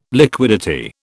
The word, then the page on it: liquidity: British English